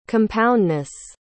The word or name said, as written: compoundness